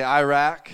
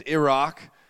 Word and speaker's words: Iraq: I learned that dumb Americans say Iraq. The proper way is Iraq.